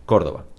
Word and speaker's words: Córdoba: We pronounce it Córdoba. How do you pronounce it in Spanish? – Córdoba.